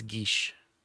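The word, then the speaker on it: Geas: But I did a quick Google search, and it's Geesh. Yeah, so like Sean Geesh. Connery pronouncing the word Geesh.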